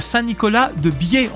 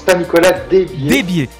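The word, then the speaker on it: Saint-Nicolas-des-Biefs: Vous êtes également maire de Saint-Nicolas de Biais, on prononce comme ça ? – On prononce Saint-Nicolas Desbiers, dans l'Allier.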